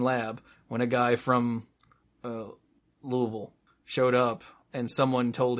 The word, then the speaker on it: Louisville: You have to say it with as little enunciation as possible. Louisville. – Yeah, you pretty much have to pronounce it like your mouth is full of chewing gum.